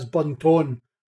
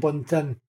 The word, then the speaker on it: Buntin: That's like pronouncing my last name as Bunton rather than Buntin.